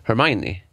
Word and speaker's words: Hermione: Hermione.